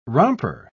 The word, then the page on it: romper: British English